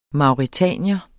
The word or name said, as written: mauretanier